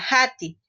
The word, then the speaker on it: hati: Ejemplo, se escribe ati con H y se pronuncia hati.